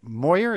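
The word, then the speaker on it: Meurer: also wrote that with Mike Moyer. Is that how it's pronounced? – Yes.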